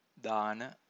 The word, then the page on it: dana: Pali